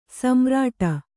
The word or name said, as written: ಸಮಾರೂಢ